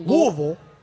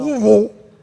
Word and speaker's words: Louisville: Did you say Louisville. It's pronounced Louisville. – No, I think it's Louisville. – Louisville. No, you go to Louisville, Kentucky, and you say Louisville, you will get punched in the face, and they'll say Louisville.